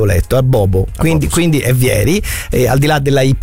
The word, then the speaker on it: Vieri: È vero che c'è Vieri senza accento sulla I, perché non è francese, no?